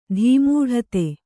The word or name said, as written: ಧೀಮೂಢತೆ